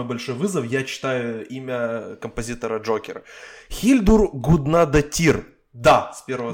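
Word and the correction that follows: Guðnadóttir: Я, наверное, не, неправильно... Гуднадотер, вот, да, спасибо. Да, да. Неправильное ударение, естественно, поставил.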